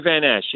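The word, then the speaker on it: Van Asch: And one of the persons you have on this show is Henry Van Osch. Am I pronouncing his last name correctly? – Yeah, pretty close. Henry Van Esch, yeah.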